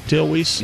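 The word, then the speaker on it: Weise: Am I saying that right? Dale Weiss? – Yep.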